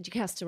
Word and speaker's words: Jacasta: You said Jacaster right, but Newnam is the...